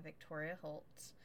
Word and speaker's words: Victoria Holt: Victoria Holt.